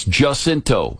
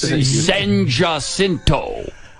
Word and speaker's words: San Jacinto: It's Jacinto. This is Houston, Texas. They don't pronounce it the Spanish way. Okay? It's San, and they do that on purpose. San Jacinto.